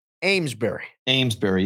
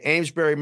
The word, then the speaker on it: Amesbury: Now, how do you say this again? Amesbury, yes. – Okay, Amesbury, Massachusetts, 50 and cloudy with a sea breeze.